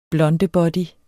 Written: blondebody

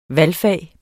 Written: valgfag

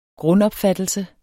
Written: grundopfattelse

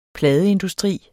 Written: pladeindustri

